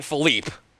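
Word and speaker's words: Phillippe: I remember Ryan Philippe, or Philippi, or Felipe. A lot of people pronounce it differently. I think it's Philippi, which is weird. But I think that's actually the way it's pronounced.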